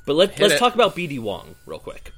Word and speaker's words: Wong: How do you pronounce that in... – Bud Wong. – Yeah, that's how you actually pronounce it.